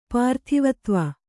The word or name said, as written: ಪೃಥ್ವೀಧವ